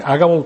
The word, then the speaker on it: Agabo: Agabo tiene que ser, porque Agabo tuviera el acento en la primera, ¿verdad? Agabo.